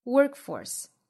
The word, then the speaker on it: workforce: 上下嘴唇弹开的同时，舌尖去找上齿龈，发一个辅音连缀，也就是一个舌边爆破的 bowl bowl disposable disposable 后面呢 workforce workforce 重读在第一个音节 workforce 中间的这个 k 的发音爆破非常轻微，因为后面是有一个阻碍的辅音哈，就是这个发音。